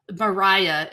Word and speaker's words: Maria: Mariah is her name, but it's spelt like Maria.